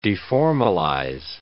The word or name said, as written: deformalize